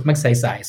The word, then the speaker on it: Magsaysay: People always butcher it, you know, Matsai Sai. And they will always say, Who's this guy? We've never heard of this guy. And I, introduced myself, oh, Joseph Magsaysay.